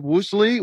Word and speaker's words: Woosley: Our next guest is Bob Woosley with Frazier and Dieter. Am I pronouncing the last name right, Bob? – Yes, you are.